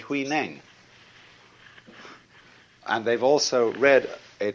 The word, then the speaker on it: Huineng: and they say Wai Lang. But this is simply the Cantonese way of pronouncing the same characters, and this is the Mandarin way. In Mandarin, Huainang becomes Wei Lang.